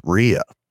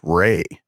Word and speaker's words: Rhea: We have been pronouncing the lead actress as Rhea, and it turns out that her name is actually pronounced Ray. Ray Seahorn is the name of Carol in real life.